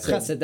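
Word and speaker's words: Chesed (חסד): hesed or hesed, I can't remember how it's pronounced. – Chesed. – That's right. Yep, we get the chesed.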